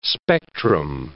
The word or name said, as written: spectrum